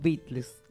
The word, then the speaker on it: Beatles: Los Beatles.